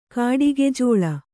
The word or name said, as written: ಕಾಡಿಗೆಜೋಳ